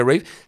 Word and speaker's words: Ralph: Si no saben quién es Ray Fiennes, porque estoy diciendo un nombre raro, porque la gente cree que es Ralph y no Rafe, se pronuncia Rafe.